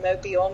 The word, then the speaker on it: Mobile: And it is pronounced Mobile, not mobile, like mobile phone.